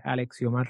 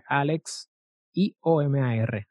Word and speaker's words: Alexiomar: Por mi, todo es por mi mismo nombre, Alexiomar Rodríguez. Se escribe Alex I-O-M-A-R, Alexiomar Rodríguez.